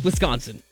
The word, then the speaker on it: Wisconsin: I said Wisconsin. They put that, like, emphasis on the C, and it's not. It's just Wisconsin.